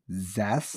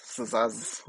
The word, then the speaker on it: Zsasz: Victor, I cannot pronounce this last name. It's spelled Z-S-A-S-Z. How would you pronounce that? Z-S-A-S-Z? Zaz. – Zaz. Zaz.